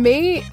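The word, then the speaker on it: Mae: I'm May,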